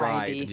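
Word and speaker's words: Reid: Whereas the actress is, it's R-E-I-D. Like ride. – Yeah. – It should be pronounced ride, assuming she's R-E-I-D.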